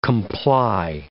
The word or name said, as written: comply